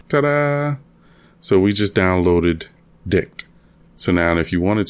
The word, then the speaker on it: Dict: So Dict is, that's spelled, because I know it may sound funny the way I'm saying it. It's D-I-C-T. And that's short for dictionary.